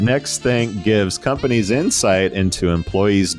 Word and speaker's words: Nexthink: This is NextThink. Did I say that right there? Guardian of Gotcha. – I think so.